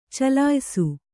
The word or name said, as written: ಚಲಿಯಿಸು